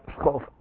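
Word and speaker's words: Schoof: That's S-C-H-O-O-F, which Dutch people will pronounce something like SOS, but the C-H is kind of tricky and the O-O is tricky. I think the F is pronounced like an F, and I think the S is pronounced like an S, but it's Dutch, so I'm not even sure about that.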